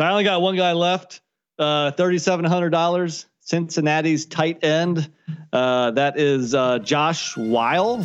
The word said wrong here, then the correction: Whyle: I think it's Wiley, right? – Is Wiley.